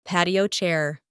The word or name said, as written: patio chair